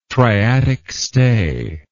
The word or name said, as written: triatic stay